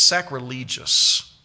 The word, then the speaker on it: sacrilegious: Now you would say sacrilegious. It's sacrilegious. If you look up the pronunciation in the NBC Handbook of Pronunciation, sacrilegious service.